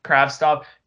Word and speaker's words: Kravtsov: If you watch the highlights of Kravtsov, is that how you say his name? Kravtsov? – Kravtsov.